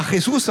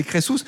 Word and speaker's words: Crésus: C'est pas Crésus, hein, c'est Crésus. Oui. Oui. Comment ça s'écrit ? – C-R-E-S-U-S.